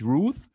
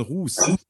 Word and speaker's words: Ruth: Nicht Ruth, sondern Ruth. – Ruth, ja, weil es keiner aussprechen kann.